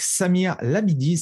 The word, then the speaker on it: Samir Labidi: Et aujourd'hui, j'ai la chance d'être avec Samir Labidi.